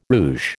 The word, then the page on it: rouge: American English